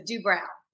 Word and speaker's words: Doobrow: He always says it's like eyebrows. So Doobrow.